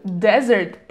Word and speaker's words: desert: Desert произносится как desert. То есть слышите, как ударение падает на самую первую часть слова. И S произносится как Z.